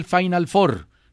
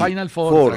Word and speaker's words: Final Four: Te sobró la U. No, la U no se pronuncia ahí. – Final Four, tranquilo.